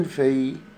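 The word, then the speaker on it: fille: إين في، في يعني بنت، ما حدش يقول عليها في ال لا تنطق.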